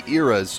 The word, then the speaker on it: Eras: And, of course, the big movie is Taylor Swift, The Eras Tour. Now, I overpronounce the E in eras because when I hear it all the time on the radio, it sounds like heiress, like A-R-R-I-S or something like that.